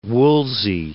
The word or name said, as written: Wolsey